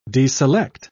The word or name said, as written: deselect